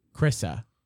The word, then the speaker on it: Carissa: We have Carissa Corbett Kavouris. – Almost all the way there. It's just two syllables, just Carissa. – Carissa.